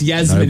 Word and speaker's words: Yasmin: Well, it's Yasmin. – Nobody cares. – It's a soft J.